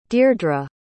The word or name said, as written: dierdre